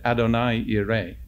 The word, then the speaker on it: Adonai Yireh: Well, she said, how would we say it? So I said, Adonai Yireh. So Jehovah Jireh is Adonai Yireh.